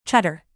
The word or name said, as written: cheddar